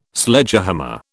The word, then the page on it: Sledgehammer: British English